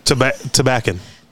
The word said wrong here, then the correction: Tabacon: That's Tabacon. – Tabacon. – You'll hear that on the Espinosa interview that we say it wrong.